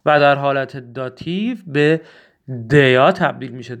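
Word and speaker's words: der: دوستان، دوستان خیلی مهمه بدونید حرف در در زبان آلمانی دیا تلفظ میشه، دیا. خیلی مهمه که خود آلمانی، خب اکثرا دوستان در تلفظ میکنن، ولی باید بدونید که خود آلمانی ها دیا تلفظ میکنن.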